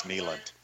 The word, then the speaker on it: Neyland: It's like Knoxvillians who say Neyland Stadium. You're wrong. It's Neyland.